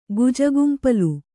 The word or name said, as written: ಗುಜುಗುಂಪಲು